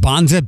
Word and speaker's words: Banza: So years ago, when I got hip to the bon, is it pronounced Banza? B-A-N-Z-A, the chickpea stuff. – I think so, yeah.